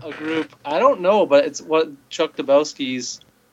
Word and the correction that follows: Dukowski: or Dukowski, pardon me.